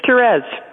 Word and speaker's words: Therese: It's Therese.